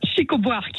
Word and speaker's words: Chico Buarque: s'appelle Chico Buarque. Chico, ouais, Chico Buarque. – Attends, comment ça s'écrit? C'est Chico. – Attends. Chico comme les dents là. – Ouais, comme les Chico. Chico Buarque. Mais je trouve pas. Comment ça s'écrit? C'est H. – B-U-A-R-Q-U-E.